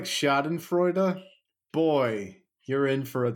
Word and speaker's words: Schadenfreude: how do you say it? – Schadenfreude. – If you like Schadenfreude. – I think you did it right the first time.